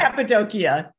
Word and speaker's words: Cappadocia: How do you say that? – Cappadocia. Good. Ah. Well, you did actually very well.